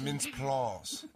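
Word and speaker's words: Mintz-Plasse: I believe it's pronounced Mince Ploss.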